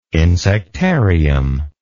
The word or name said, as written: insectarium